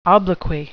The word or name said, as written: obloquy